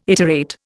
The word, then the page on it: iterate: Indian